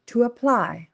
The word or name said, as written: to apply